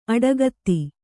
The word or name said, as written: ಅಡಕೊತ್ತು